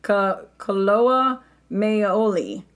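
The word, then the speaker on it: Koloa maoli: Hawaii's wetlands are actually habitat for endangered and endemic birds, including the Hawaiian black-necked stilt, the Laysan duck, and the Hawaiian duck, or the Kaloa Maia. Uh-oh, this is Hawaiian. Sorry for those of you who know how to say this. I don't want to butcher it. Kaloa Maoli. Kaloa Maoli.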